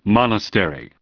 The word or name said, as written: Monastery